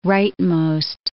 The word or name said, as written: rightmost